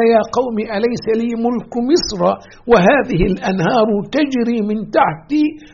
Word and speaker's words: مصر: أيضا أحبتي في الله كلمة مصر، احنا بنقول مصر في لغتنا الدارجة، مصر مصر مصر، ومصر أم الدنيا، ومصر كذا وكذا. النطق الصحيح لهذه الكلمة كما في كتاب الله جل جلاله مصر: وقال الذي اشتراه من مصر لامرأته أكرمي مثواه.